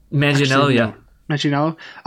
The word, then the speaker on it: Manganiello: Then Joe Manganiello, is that how you pronounce his name? I've never Manganiello.